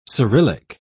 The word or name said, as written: cyrillic